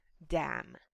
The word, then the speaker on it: damn: Cholercia piszemy d-a-my-ny. Cholercia, tego ny nie czytamy i wymawia się tak samo jak damn.